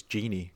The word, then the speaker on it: Gini: Gini as in Wijnaldum, not lamp.